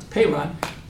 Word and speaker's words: Paran: The desert of Paran, that's how you pronounce Paran, will be the staging area for the long-awaited attack upon the land of Canaan.